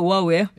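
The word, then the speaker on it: 오아우 (Oʻahu): 원어 발음도 오아우예요.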